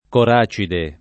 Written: coracide

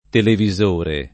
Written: televisore

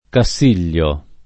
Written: Cassiglio

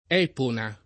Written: Epona

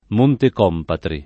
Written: Montecompatri

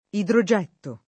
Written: idrogetto

